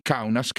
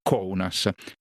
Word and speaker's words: Kaunas: che è ambientato nei sobborghi di Kaunas, che però si pronuncia Kaunas, la seconda città più grande del paese, città dove peraltro lei stessa è cresciuta.